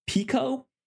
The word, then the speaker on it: Pekoe: But interestingly enough, one of them, the title of it, I believe, is pronounced Pico, spelled P-E-K-O-E.